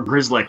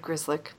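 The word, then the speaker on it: Grzelcyk: And unless we expect O'Gara or Grizzlick, I said it right the first time for once, to make the jump next year, which we don't. – I'm very impressed that you said Grizzlick on the first shot. Very good.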